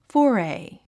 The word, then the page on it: foray: fɔ’ːrei; フォーレイ